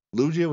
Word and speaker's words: Lugia: Lugia with a soft G? – Either way. – Okay. – Whatever suits.